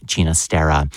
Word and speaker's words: Ginastera: Another 20th century ballet, this the work of composer Alberto Ginastera, Argentinian composer of Catalan ancestry, hence that pronunciation of his last name, Ginastera.